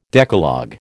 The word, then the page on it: Decalogue: American English